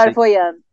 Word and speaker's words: Clairvoyant: que é The Clairvoyant. Como é que fala? Não sei. – Clairvoyant.